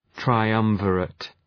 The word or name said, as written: triumvirate